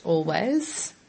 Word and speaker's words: Alvvays: This one is a pretty recent release by a group called, well, it's spelt A-L-V-V-A-Y-S, but I'm pretty sure it's pronounced Always.